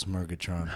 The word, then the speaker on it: Murgatron: three syllables, Murgatron.